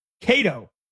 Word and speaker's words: Kato: They put Kato, K-A-T-O in all tacks and in all caps. Kato or Kato, however you want to pronounce it.